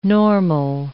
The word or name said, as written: normal